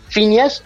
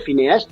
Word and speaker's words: Phineas: Y muy bien que es verdad, la pronunciación correcta es Phineas, en inglés es Phineas. Pero aquí cuando uno lo lee dice Phineas.